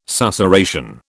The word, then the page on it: susurration: British English